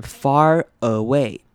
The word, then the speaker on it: far away: 那 far 的 ar 是念 r 的声音，但是后面 away 开头的 a 呢，却是念成轻音 a，所以我们会念成 far away。